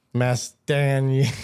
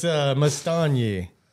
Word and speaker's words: Mastagni: It looks like Mastagni. – That's how I always say it. – But the G is silent. That's the trick. So it's Mastagni.